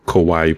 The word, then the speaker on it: Kawaii: It's like the word Hawaii, but spelled with a K because it's like K-pop Hawaii. So it's Kawaii Pop.